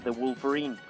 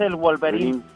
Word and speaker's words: The Wolverine: Se dice The Wolverine. Ajá. The Wolverine. – The Wolverine. – No, es el Wolverine. – No, es The Wolverine.